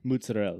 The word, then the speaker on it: mozzarella: Mozzarella. There's no A on the end of it.